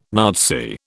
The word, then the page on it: Nazi: British English